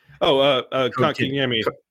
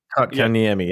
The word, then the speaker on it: Kotkaniemi: Kotkaniemi. – Kotkaniemi. – Kotkaniemi, yeah.